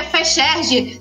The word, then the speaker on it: FEXERJ: curtam, curtam a gente no Facebook, que é Fecherge Se escreve F-E-X-E-R-J, porque o RJ é do Rio de Janeiro.